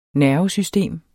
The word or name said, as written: NERVESYSTEM